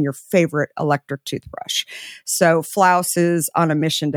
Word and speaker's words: Flaus: So kind of like house, spelled sort of like that word floss, but it's actually pronounced Flaus. So F-L-A-U-S.